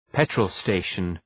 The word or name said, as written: petrol station